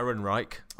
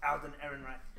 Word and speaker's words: Ehrenreich: Ehrenreich. I learned how to pronounce that just for this podcast. I'm just going to say it again. – Alden Ehrenreich. And the thing you can all do it now.